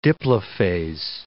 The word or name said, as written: diplophase